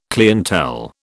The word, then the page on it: clientele: British English